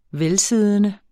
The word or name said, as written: velsiddende